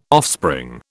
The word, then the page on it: offspring: British English